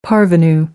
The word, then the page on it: parvenu: American English